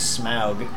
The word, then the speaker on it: Smaug: I mean, in all honesty, even Bilbo's encounter with Smaug is grits saying the word.